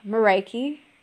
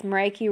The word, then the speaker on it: Mareike: I'm Mareike, last name is Schleifer. When I meet people, I say it's Mareike rhymes with Crikey or Mariah Carey.